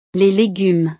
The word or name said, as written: les legumes